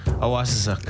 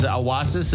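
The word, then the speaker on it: awasisak: Means children. Not just one child, because awasis is one child, but if you say awasasuk, it becomes plural.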